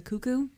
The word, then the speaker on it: Cuckoo: The Knights of the Cuckoo is how it's pronounced. – I don't think it's spelled that way. – I'm pretty sure that's how Iji pronounces it. – Oh, did he say it? – Yeah, once? – He does. – He didn't say cucks. – No, he says cuckoo.